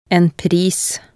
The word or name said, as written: en pris